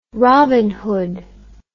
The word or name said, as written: robin hood